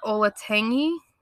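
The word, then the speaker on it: Olentangy: I think it's pronounced Olatangy.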